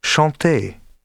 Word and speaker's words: chanter: Keep in mind as well that final ER is sometimes pronounced like French R, but often pronounced like E. Fier, but chanter, danser, parler.